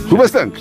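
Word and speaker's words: Hoobastank: Hubastank. Hubastank. – Pues sí. – ¿Se dice así? ¿Hubastank? – Se dice así.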